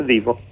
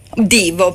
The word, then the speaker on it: Devo: Clock Out, loro sono i Devo, anzi, si pronuncia Devo o Divo? Chiedo a te. – Si pronuncia Divo. – Divo, perfetto. Ammetto subito la mia ignoranza sulle pronuncia di determinati gruppi.